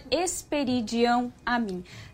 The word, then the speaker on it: Espiridião Amin: Eu quero corrigir também aqui minha pronúncia. É senador Espiridião Amin.